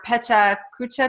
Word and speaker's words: Pecha Kucha: She was invited to speak on expectation versus realization at the Institute of Contemporary Arts in London, for Pecha Kucha Nights.